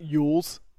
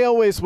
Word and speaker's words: Elwes: Cary Elwes. – Yeah, it's not Yules. Yeah. Everybody knows that. – Who would say that? What an idiot. – So Cary Elwes was in The Princess Bride.